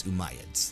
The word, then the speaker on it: Umayyads: I said it wrong. It's Umayyads.